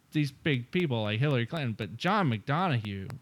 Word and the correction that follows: McDonough: Wait, how do you say his name? – I believe it's McDonough.